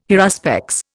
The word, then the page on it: haruspex: Indian